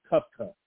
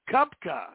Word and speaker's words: Koepka: But how do you pronounce that guy's name? Is it Koepka? – Brooks Koepka. – Koepka, yeah, Brooks.